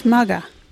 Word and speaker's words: maga: Our local Wagyu word is mugga. What is mugga? Mugga is your head. You can use mugga in this sentence. Put that hat on your mugga. Mugga is spelt M-A-G-A.